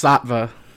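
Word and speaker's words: Saatva: It's pronounced Sattva.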